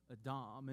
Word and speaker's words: Adam: The word Adam in Hebrew is the word Adam, and it simply means mankind. It's not necessarily a personal name. It's this idea of humanity together, and it's pronounced Adam.